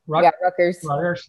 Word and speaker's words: Rutgers: Rutgers.